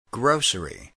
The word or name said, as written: grocery